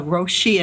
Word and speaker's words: Roshi: And I have to ask, my very first question is, what is a Roshi? And am I saying that right? – You are saying it right.